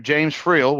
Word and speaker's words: James Freel: And today I've got Mr. James Friel with us.